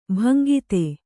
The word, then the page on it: ಭಂಗಿತೆ: bhangite